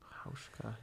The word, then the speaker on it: Hauschka: Hauschka. It's spelled Hauschka. – Hauschka, yeah.